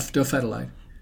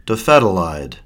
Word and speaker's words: dofetilide: Dofetilide. – Dofetilide.